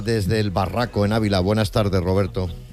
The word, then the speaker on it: El Barraco: Desde el Barraco, Ávila.